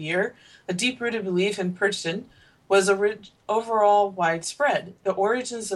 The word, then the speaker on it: Perchten: So that's spelled P-E-R-C-H-T-E-N. Perchten. – Perchten's pretty close, yeah.